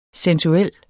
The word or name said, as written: sensuel